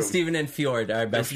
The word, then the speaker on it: fjord: But it means down by the fjord. Ford? – Fjord. – Like the river.